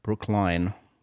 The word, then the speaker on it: Brookline: Brookline, yeah.